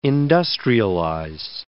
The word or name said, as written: industrialize